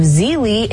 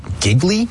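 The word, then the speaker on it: Gigli: Lopez and Affleck reportedly first met on the set of Geely in 2002. – First met on the set of... Giggly? – Geely? Is that what it is? Geely? Second G is silent.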